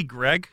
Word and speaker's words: Greig: because Ridley Gregg. – I know. – Is pronounced Ridley Greig in the mm-hmm. pronunciation guide, but everyone under the sun is pronouncing it Greg.